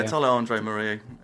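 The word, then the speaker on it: Tala André Marie: Is it Tala AM? Is that how you say it? – Yeah, Tala Andre Marie.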